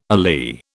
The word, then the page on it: alee: British English